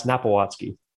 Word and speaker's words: Napiwotzki: It's Napowatzky.